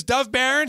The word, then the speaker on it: Dov Baron: You know, the copy says pronounce it Barone. They put... All right, do not cut that part out. His name is Dov Baron.